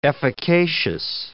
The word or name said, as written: efficacious